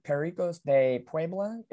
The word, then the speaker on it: Pericos de Puebla: pardon me if I get the team name a little off here, the Pericos de Puebla. Is that how we pronounce it specifically? – Yep.